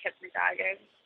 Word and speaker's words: hypnagogic: I was when I was reading it, it was funny because it's particularly the, hypnagogic, is that how you say it? Hypnagogic? – Yeah. I say like hypnagogic.